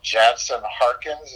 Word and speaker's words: Jansen Harkins: I always get this guy's name wrong. Jansen Harkins, is that how you say it? – And, that is, yes.